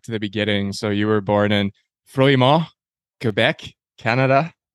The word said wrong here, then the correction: Fleurimont: Yeah, it's Fleurimont, in to say it right.